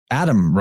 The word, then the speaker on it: Atem: was Adam Ra, spelled A-T-E-M, but pronounced it almost exactly as Adam, proving quite well that the T and the D can be interchanged as, you know, a lot of letters interchange.